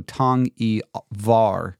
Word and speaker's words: Tang-e-Var: It's spelled T-A-N-G-I, new word, V-A-R, so Tang-e-Var inscription, which is found in the Tang-e-Var Pass in Iran.